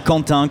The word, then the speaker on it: Quentin: Mais comme mon prénom aussi, Quentin, cantine.